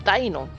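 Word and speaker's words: Deino: Se pronuncia Dino porque este Pokémon, su nombre está basado en los números alemanes Ein, Zwei y Drei, que son 1, dos y tres. – Oh por eso es Daino, porque es Ain.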